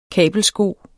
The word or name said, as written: kabelsko